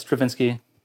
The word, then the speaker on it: Stravinsky: Stravinsky? – Yeah. – I said it right? – Yes, Stravinsky.